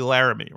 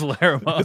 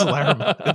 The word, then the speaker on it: Larrimah: The town's name's actually Laramie, right? But no, I think it's Larrimah. it's Larrimah. – It's spelled Larrimah.